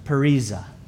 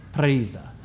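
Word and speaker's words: parisa (παρρησία): The word for boldness or courage is transliterated in Greek as parisa. Parisa. I can't even say it right. Parisa. Let's say it together on the count of three. One, two, three. Parisa.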